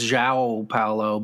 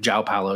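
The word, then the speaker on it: João Paulo: Yeah, well, the official pronunciation on the sheet is Jao Paulo, but I think Jao Paulo is just what we say.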